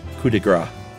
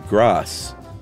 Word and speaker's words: coup de grâce: Gras in French is fat, with a silent last letter, like foie gras, instead of grasse. Silent E, but a soft C. Loosely sounds like grass.